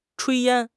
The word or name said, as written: 炊烟